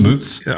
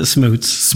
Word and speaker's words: Schmutz: It is Schmutz. Our family pronounces it Schmutz.